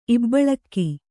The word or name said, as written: ಇಬ್ಬಳಿಗೆ